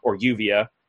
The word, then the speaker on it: Juvia: Juvia is how you're really supposed to say her name, and she loves him, but he's like you'll find out what a tsundere is.